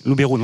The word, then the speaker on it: Luberon: Voilà, Luberon. – Simplement parce qu'on dit biberon et pas biberon. Voilà. – Oui, c'est parce que ça, Luberon, en provençal, c'est Luberoun. – Luberoun, oui.